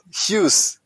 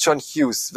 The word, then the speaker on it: Hughes: 9 Punkte, der John Huge. Huge, das ist ein Scheißname. Wieso kann er nicht Huge oder wie spricht man den wirklich aus? – Hughes. – Sagt er selber, mein Name ist John Hughes, weil man weiß es nicht genau, gell?